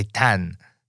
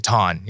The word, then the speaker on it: Thanh: I would always ask anybody who is from Vietnam, "How do I say my name? How does T-H-A-N-H say?" They say Tan. Tan. It's like, okay, no, I'm going to keep it Ton.